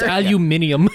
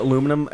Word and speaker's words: aluminium: It's aluminium. – Aluminium. Okay. No, it's color, not color. – You know why Americans pronounce it aluminum and the British pronounce it aluminium?